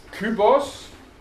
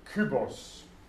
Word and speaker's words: Kybos: Sie sehen, über dem O ist keine Längung, also muss es ein kurzes O sein, Omikron. Und hinten dann noch ein Sigma Kybos. Ja? Kybos der Würfel.